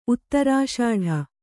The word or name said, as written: ಉತ್ತರಾಷಾಢ